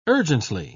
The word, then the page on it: urgently: British English